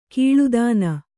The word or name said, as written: ಕೀಳುದನಿ